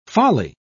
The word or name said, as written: folly